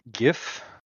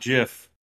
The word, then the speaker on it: Giff: I think it's, how do you pronounce it? Giff. Is that how you pronounce it? – Definitely not Jiff.